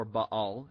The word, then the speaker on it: Baal: Baal was the main chief male deity, or Baal, as is properly pronounced, and Ashtoreth was the main chief female deity.